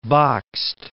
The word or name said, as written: Bakst